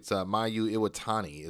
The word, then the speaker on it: Mayu Iwatani: But speaking of Mercedes Moné, obviously you guys have heard recently she lost her IWGP women's title to Mayu Iwatani is how you pronounce it.